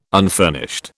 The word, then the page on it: unfurnished: British English